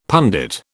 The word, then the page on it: pundit: British English